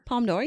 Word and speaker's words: Palme d'Or: And then he won the, please pronounce it better than me, Palme d'Or? – Palme d'Or, yeah.